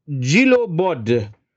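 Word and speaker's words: Djilobodji: Le Parisien berichtet, dass der BVB an Nantes Papi Gilo, also das haben wir schon mal, D-J-I-L-O, Gilo B-O-D-J. – Bodge, ja. Ich würde das J vielleicht auch gar nicht aussprechen. Bodge. – Gilo Bodge.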